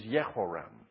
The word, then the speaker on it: Jehoram: The J is usually pronounced as a Y in Hebrew. So this guy is Jehoram, the son of Ahab and Jezebel.